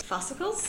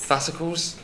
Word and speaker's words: fascicles: But their trunk as well contains up to 150,000 separate Oh, I'm going to mispronounce this word. I'm so dyslexic sometimes. – Fascicles? – Fascicles, there you go.